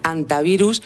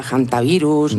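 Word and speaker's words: hantavirus: No es infrecuente oírla quizá de ambas formas, pero lo adecuado es en efecto dejarla muda, decir antivirus y no aspirarla, jantavirus, ni nada similar.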